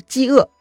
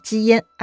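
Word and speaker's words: 姬阏: 姬嘛就是郑国国姓，鄂呢是门字框里头淤泥的淤的右半边，哎，这个字呢另一个读音叫做燕。所以啊，这位子都的大名呢，可能是饥饿，也可能是饥烟，哎，听上去反正都怪怪的。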